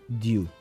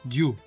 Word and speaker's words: Diu: Diu. Diu. D-I-U.